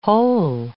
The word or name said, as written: whole